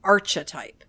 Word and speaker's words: archetype: So the word is pronounced archetype, not archetype. It starts with arc, like a curve, not arch, like a bridge.